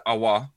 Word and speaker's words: Aouar: I got a telling off the other day because apparently I was saying his name wrong. So I believe it is Aouar. Am I right? Is that right? That is right.